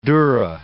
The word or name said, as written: durra